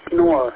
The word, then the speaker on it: Kehnor: K-E-8, what else? – November Oscar Romeo. Kilo Echo 8, November Oscar Romeo. So it's like Knorr, how you say my name. – Got it, Knorr. K-E-H-N-O-R.